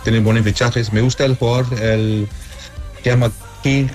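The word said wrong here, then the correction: Kang-in Lee: Ah, espera, a ver si me recuerdo pronunciar su nombre correcto. Se llama Kang In-Lee.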